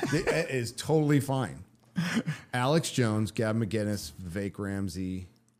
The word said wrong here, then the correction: Ramaswamy: Ramaswamy. I always say Ramsey.